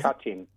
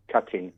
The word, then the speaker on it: Katyn: Katyn. – Katyn. – Katyn.